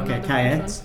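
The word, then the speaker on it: Kajet: So it's not the French pronunciation. No, Kayet. So tell us, what is this magazine all about?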